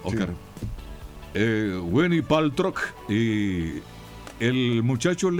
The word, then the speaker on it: Gwyneth Paltrow: Y la actriz, si mal no recuerdo, no sé cómo se pronuncia bien ahí, Gwyneth Paltrow, algo así. – Gwyneth Paltrow, sí.